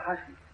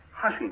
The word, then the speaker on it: হাসি: লাভ, লাভ মানে তো হাসি। – এখানেও নেই ওই চন্দ্রবিন্দুটি, শব্দটা হাসি, হাসি না।